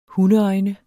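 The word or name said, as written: hundeøjne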